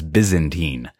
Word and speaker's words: Byzantine: and they're I think the way that you pronounce the word is Byzantine.